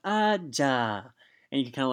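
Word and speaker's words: ja (じゃ): Well, that well in Japanese is pronounced ja.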